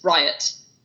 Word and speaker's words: Riot: Because it's like, I'll say my name, and now I've gotten used to saying Riot like the police. – Right. – Even if I spell it out, I'll go, it's Riot, R-I-O-T.